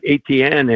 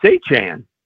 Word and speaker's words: Achane: And in his first press conference, you know, he says it's not ATN anymore. And I live not far away from a family of ATNs. They spell it the exact same way, said it that way. And he said, it's Achane.